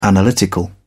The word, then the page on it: analytical: British English